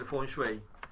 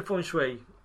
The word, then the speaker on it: Feng Shui: Decía, Feng, acuérdense como un fan, ¿no? Como un ventilador. Entonces ese es viento. Y Shui, él decía como shower, ¿no?